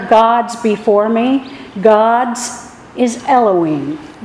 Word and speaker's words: Elohim: And that first word would be Elohim. In English, the way we would spell it, E-L-O-H-I-M.